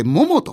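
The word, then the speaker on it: 百: この黒崎にある 100 と書いてモモというお店、このお店に行ってきたそうでございます、はい、その模様をどうぞ。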